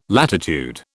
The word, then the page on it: latitude: British English